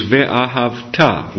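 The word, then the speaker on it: ve'ahavta (וְאָהַבְתָּ): And since we recite the Shema in Hebrew here, I would encourage you all to say it according to this language, which is ve'ahavta. The Shema and the word ve'ahavta in biblical Hebrew is ve'ahavta, with the accent on the ta.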